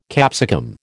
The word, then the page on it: capsicum: American English